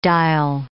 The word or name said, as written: dial